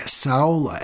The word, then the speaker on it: saola: Lori, there is an animal called a saola, S-A-O-L-A. Saola, some people say saola. That's a couple of different pronunciations.